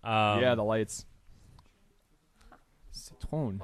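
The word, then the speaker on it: Citroën: Eiffel Tower Citrion, I believe. Kind of phonetically as I'm saying that. – Eiffel Tower Citrion. I think it's Citron. – Citrion. – Citron. – There we go.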